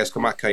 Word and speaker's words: Scamacca: He should have, I think it's Scamacca, scored, isn't it? – Yeah, Scamacca, yeah.